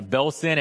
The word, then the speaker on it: Belsen: See, in prison 22, what the Allies had done is they had taken photographs from Belsen. Wait, did I say that right? Yeah, Belsen and Auschwitz, some of the worst of the concentration camps, and they had posted these photos without comment around the prisoner camp.